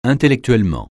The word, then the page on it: intellectuellement: francais